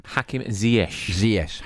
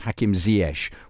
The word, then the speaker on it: Hakim Ziyech: Hakim Ziyech. – Hakim Ziyech.